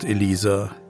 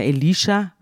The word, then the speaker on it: Elisha: Wir haben jetzt hier verschiedene Aussprachen, wir haben es ja jetzt gehört, ich sage immer Elisha, weil in meiner Bibel steht Elisha und in der Übersetzung von Luther steht Elisa, also das wird unterschiedlich ausgesprochen.